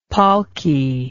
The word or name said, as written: palki